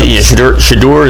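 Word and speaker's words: Shedeur: Shadur, is that how you say his name? Sanders. – Yes.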